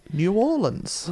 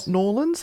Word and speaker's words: New Orleans: Got to say it right. New Orleans. – Well, New Orleans. – Not Norlands. They don't like Norlands. They like New Orleans.